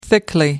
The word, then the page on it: thickly: British English